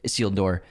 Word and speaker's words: Isildur: Isildur.